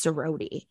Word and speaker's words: Ssrody: The first one that I'm going to talk about is Sarody. So it's spelled S-S-R-O-D-Y. And it's pronounced Sarody.